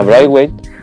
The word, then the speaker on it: Braithwaite: Bra A Braithwaite. Sí, es, como, es como decir cerebro y White.